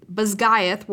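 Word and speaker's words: Basgiath: Bazgaeth War College, Violet included.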